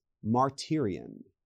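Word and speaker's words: martyrion (μαρτύριον): When John says he is witnessing something, and then he wants to testify to what he witnessed, the Greek word being used there is martyrion. Martyrion. And it's spelled M-A-R-T-Y-R-I-A-N. Did you get that? M-A-R-T-Y-R.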